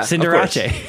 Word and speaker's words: Cinderace: Cinderace is pretty good. Inteleon's coming to unite, you know, all those good things. – Did you say Cinderace? – Yeah. – Okay. – Like Liberace. – Yeah. – Cinderace.